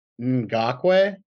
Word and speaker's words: Ngakoue: Yeah, how do you say this guy's last name? – I believe it's Ngakoue.